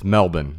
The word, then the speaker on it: Melbourne: But I know in Australia, it's Melbourne. Melbourne, Australia. Although in Scotland, that's how they pronounce my last name too.